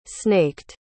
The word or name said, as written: snaked